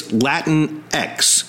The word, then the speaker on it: Latinx: You may have wondered how to pronounce it. Basically, it's Latinx. It's the word Latino, L-A-T-I-N-O, with the O changed to an X, and there's a big issue behind that.